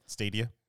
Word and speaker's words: Stadia: Stadia.